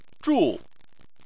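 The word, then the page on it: zhu: tone 4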